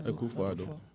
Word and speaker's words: Akufo-Addo: What's your take on, I don't want to mispronounce his name, President Addo? – Yes, Akufo-Addo.